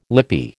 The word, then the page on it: lippy: American English